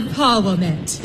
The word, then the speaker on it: Parwomet: I call it Parwomet. – You call it what? – Parwomet, after the three of them. Paramount, Warner, Metro-Goldwyn, the first syllable of each.